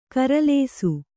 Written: ಖರೆಲೇಸು

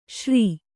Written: ಶ್ರೀ

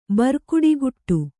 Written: ಬರ್ಕುಡಿಗುಟ್ಟು

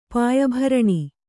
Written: ಪಾಯಭರಣಿ

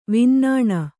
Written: ವಿನ್ನಾಣ

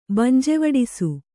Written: ಬಂಜೆವಡಿಸು

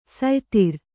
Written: ಸಯ್ತಿರ್

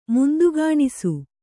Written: ಮುಂದುಗಾಣಿಸು

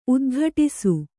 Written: ಉದ್ಘಟಿಸು

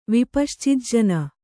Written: ವಿಪಶ್ಚಿಜ್ಜನ

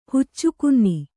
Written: ಹುಚ್ಚುಕುನ್ನಿ

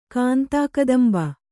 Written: ಕಾಂತಾಕದಂಬ

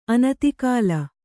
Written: ಆನೆದೊಗಲು

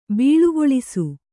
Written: ಬೀಳುಗೊಳಿಸು

